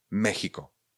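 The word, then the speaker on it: México: No, Mexiko se vyslovuje s ch, Mexiko, Mexiko.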